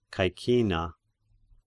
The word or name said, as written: Caecina